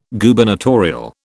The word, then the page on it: Gubernatorial: British English